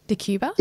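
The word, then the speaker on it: Decjuba: Is that how you say it? – Yeah. – DeCuba? – DeCuba. Yeah.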